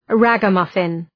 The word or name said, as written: ragamuffin